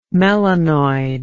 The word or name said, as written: melanoid